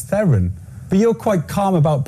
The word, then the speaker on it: Theron: Am I saying it right? Is it Theron? – Yes.